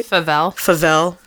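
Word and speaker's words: Favell: Then we have Jack Flavel. Did I say that right? – Favel.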